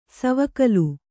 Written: ಸವಿಗೋಲ

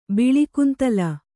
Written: ಬಿಳಿಕುಂತಲ

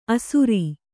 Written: ಅಸುರೀ

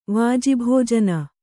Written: ವಾಜಿಭೋಜನ

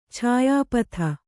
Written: ಛಾಯಾಪಥ

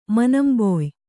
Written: ಮನಂಬೊಯ್

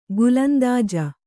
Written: ಗೋಲಂದಾಜು

